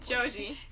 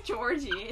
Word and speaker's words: Joji: How the fuck do you say his name? I'm like, Joji. – Joji. – It's not Georgie.